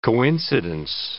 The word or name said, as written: coincidence